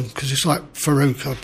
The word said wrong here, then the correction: Varadkar: but I shouldn't be... – Varadkar from Southern Ireland.